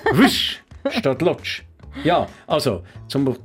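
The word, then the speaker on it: Łódź: Und Lodz heißt eben auf Polnisch gar nicht lutsch, sondern wisch.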